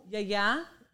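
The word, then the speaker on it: Yaya (γιαγιά): Yaya.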